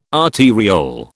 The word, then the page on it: arteriole: British English